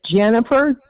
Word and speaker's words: Jennifer: My name is Jennifer, J-E-N-N-I-F-E-R. – And the last name is spelled? – Connor, C-O-N-N-E-R.